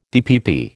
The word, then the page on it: DPP: American English